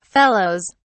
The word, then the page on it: fellows: British English